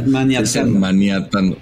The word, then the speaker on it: maniatando: Entonces, en este partido de Navidad, San Francisco creo que empezó bastante bien el juego, medio manateando. Sí, ¿cómo se dice? Maniatando. – Porque el otro es cuando estás con un manatí maniatándotelo, ¿no? Pero esto es maniatando.